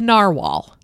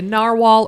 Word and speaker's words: narwhal: The narwhal. – Oh, yeah. The unicorn of the ocean. – The unicorn of the sea. The narwhal or the narwhal. It's another alternative spelling and pronunciation.